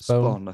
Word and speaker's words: Spohn: I think it might have been Steven Spohn." Um, is that is that pronunciation? Sorry, Steve Spohn. Spawn. I think. Steve Spawn.